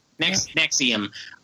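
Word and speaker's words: NXIVM: On 27th, and how do you say it? Nixivum cult leader? – Yeah, Nexium? – Nexium, sentenced to 120 years.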